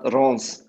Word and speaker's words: Reims: Ja, es wird, es wird mir auch schwer dran, aber es wird Stade Reims ausgesprochen.